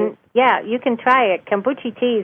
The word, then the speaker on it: kombucha: Kombucha tea, yeah.